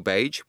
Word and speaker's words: Bage: For those of you who don't know very much about Neil Bage, with a hard G,